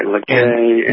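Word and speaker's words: Les Cayes: Lacay and the town of Jeremy and that whole southern peninsula, what's it called, the Tiburon Peninsula, was just devastated on October 4th.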